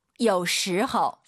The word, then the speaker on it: 有时候: 有时候. – 삼성이성 아아 들려야 됩니다. 有时호 아니죠. 오유는 어우 발음이에요. 허우예요.